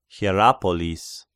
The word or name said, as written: Hierapolis